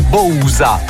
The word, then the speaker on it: Bausa: Loro, quelli di Magnetic, si chiamano Bausa ma si leggono Bousa, quindi un po' si assomigliano.